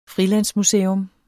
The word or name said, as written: frilandsmuseum